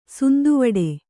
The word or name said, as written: ಸಂದುವಿಡು